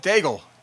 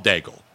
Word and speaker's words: Daigle: Daigle. Yes. – It's Daig. It's pronounced Daig. It looks like it's spelled Daigle. But it's Daig.